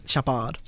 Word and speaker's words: Shepard: Shepard. That's my stage name. Same spelling, but just pronounced differently.